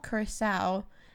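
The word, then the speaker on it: Curaçao: Curacao, and Sprite.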